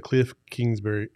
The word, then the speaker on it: Kingsbury: I think this is the most important game in the Cliff Kingsbury era.